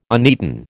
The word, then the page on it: uneaten: American English